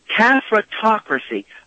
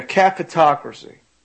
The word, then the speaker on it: cafatocracy: We have Florida on the line, and was recommending an idea called a halfocracy. Is that what it is? – No, caffetocracy. Like spell it for me. – Okay, C-A-F-A-T-O-C-R-A-C-Y, like a cafeteria. – I gotcha, caffetocracy.